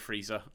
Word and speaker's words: Friese: I have to say to that, though, that's the German pronunciation of those names. – It can be that the heritage of these words is something different. I don't know. – Okay, well, I'm gonna plead ignorance on that. I've been told by a German person that it is that, and until someone someone corrects me again, I'm gonna go with Friese.